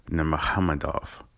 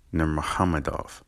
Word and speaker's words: Nurmagomedov: It's not Habib. It's not Khabib. Like I just said, it's Habib. And it's not Nurmagomedov, it's Nurmagomedov. Okay, so, you know, it's like Muhammad, Nurmagomedov.